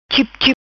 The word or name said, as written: Chipchip